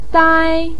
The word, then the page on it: dai1: pinyin